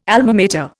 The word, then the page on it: Alma Mater: Indian